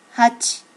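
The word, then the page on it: hachi: native Japanese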